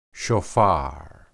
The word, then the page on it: shophar: hebrew